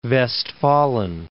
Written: Westfalen

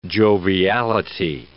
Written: joviality

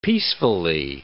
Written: peacefully

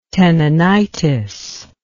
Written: tenonitis

